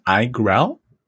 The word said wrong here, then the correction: iGraal: Yeah, iGraal. – iGraal, yeah.